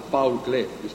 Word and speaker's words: Paul Klee: Molto brevemente, l'hanno già detto altri difensori, non mi voglio dilungare, l'operazione la Paul Klee, abbiamo scoperto che si dice così, almeno pronunciamola giusta, ecco, una volta si pronuncia quasi all'italiana, ecco, nessun inglesismo.